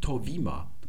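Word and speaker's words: Torvima (Το Βήμα): Und da seht ihr gleich, dieses Äther wird ja heute wie ein I ausgesprochen. Das Beta nicht mehr wie ein B, sondern wie ein W. So sagt man also heute im Griechischen Torvima können wir uns mal einen kleinen Blick erlauben auf diese Zeitung, die Internetseite.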